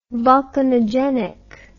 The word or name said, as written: volcanogenic